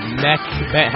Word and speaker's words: Mech Bank: M-E-C-H-B-A-N-K. Mech Bank. – Mech Bank. How do you say it? – Mech Bank. All one word. – How about just one more time? – It's Mech Bank. One word.